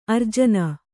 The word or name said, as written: ಆರ್ಜನ